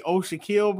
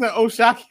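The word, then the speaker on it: O'Shaquie: I've been trying to make sure that I say his name and not say Oshaquille because it makes you want to say Oshaquille every time you see it.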